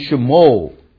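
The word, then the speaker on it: shmo (שמו): 那在我们的课本 page 9 啊就有说明了。那么当这个 Shiva begins the syllable 啊，我们注意这个 simple Shiva 啊 paragraph 1 啊，他在这里举个例子，你看到 Shmo 这个字吗？ Shmo，那你听读的时候你发现这个 Shiva 有没有发音啊？有没有发音？有没有发音这个字？这个许话有没有发音？有发音，有 shmo 啊，有发音啊。那这个是属于 vocal 许话。那什么时候发音呢？他在这里就解释，the 许话 in shmo and shumarim begins the syllable with a quick vowel-like sound.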